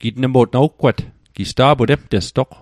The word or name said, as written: gitnmo'tg